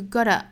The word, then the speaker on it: gotta: You know what you gotta do 这个 gotta 我们注意它是 gotta 后面的这个 t 的发音其实更倾向于发成一个 flat t 啊，就是弹舌的音 gotta You know what you gotta do?